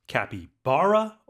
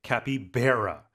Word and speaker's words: capybara: Today, it's a capybara, pronounced capybara or capybara.